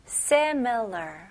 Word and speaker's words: similar: 발음 연습부터 해볼게요. se, me, 그 다음에 l 발음, ler. 네, 끝까지 r 발음을 힘을 주시면서 마무리하셔야 돼요. similar. 오케이? 강세는 앞부분이에요. similar. similar.